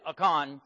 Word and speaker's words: Achan: And those are two long A's there in Hebrew, so it is Achan.